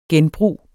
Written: genbrug

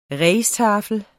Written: rijsttafel

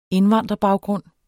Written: indvandrerbaggrund

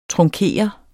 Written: trunkere

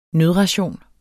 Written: nødration

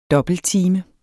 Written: dobbelttime

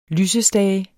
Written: lysestage

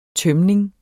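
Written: tømning

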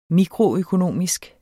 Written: mikroøkonomisk

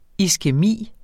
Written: iskæmi

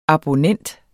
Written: ABONNENTS

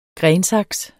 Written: grensaks

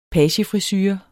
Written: pagefrisure

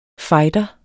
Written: FIGHTERES

